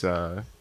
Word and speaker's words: Ellex: So, and I always mispronounce it. It's Ellex. – It's like Ellex with an E.